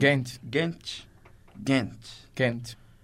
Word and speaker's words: Gent: Da onde é esse time? Da Bélgica. Da Bélgica. Sotaque belga, Gent. cara. Tem que usar o sotaque certo, belga.